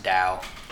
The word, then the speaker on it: Tao: It's pronounced Dao. – Dao. – Listen. – Dao. – Okay, Google, to be fair, this is spelled T-A-O, and Google also says that it can be pronounced Tao.